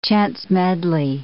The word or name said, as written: chance-medley